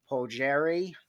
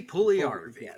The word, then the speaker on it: Puljujärvi: They sent a prospect to Edmonton and got Jesse Puljujärvi. – Jesse Puljujärvi. Sorry, I should have jumped in earlier to save you.